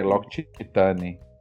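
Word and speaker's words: L'Occitane: Aquela, aquela de, de creme, lo, Octane, lá, Octane. – É, Loctitane.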